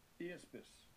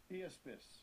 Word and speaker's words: Iaspis: And so jasper, let's hear the pronunciation in the European Greek. – Strong's G 2393. Iaspis. – Iaspis.